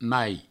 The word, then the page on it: emai: Provençal